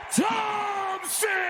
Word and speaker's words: Thompson: I was speaking of the Golden State Warriors player and referred to him as Clay Thomas. More than a few listeners doubted my Thomas, as well they should. Here's the true pronunciation of that versatile gentleman's name. – And now, in his 11th NBA season, out of Washington State University, number 11, Clay Thompson!